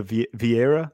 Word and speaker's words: Vieira: His name, the Vieira, the coach.